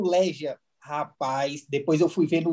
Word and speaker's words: Legia: Mas Legia é a pronúncia correta do clube. – É, Legia. Aqui, aqui no, na Polônia eles costumam falar Legia.